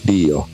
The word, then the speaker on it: Dio: How do you pronounce Dio? Dio.